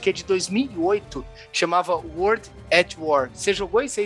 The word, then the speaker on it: World at War: Me ajuda aí na pronúncia, mano. World at War. Boa, é isso aí, galera.